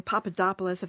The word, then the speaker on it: Papadopoulos: Papadopoulos, if that's how you pronounce his name. – That's how you say it, He was lying.